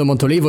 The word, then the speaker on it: Montolivo: To start with, you can hear how the names of Montolivo and Prandelli are meant to be pronounced. – I mean, Riccardo Montolivo is going to be out, and he's Prandelli's favourite.